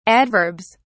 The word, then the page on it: adverbs: British English